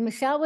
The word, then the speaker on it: Michele: It's Michele with one L. We already talked earlier about my name is spelled weird. Michele with one L, M-I-C-H-E-L-E, and Cushatt with two T's.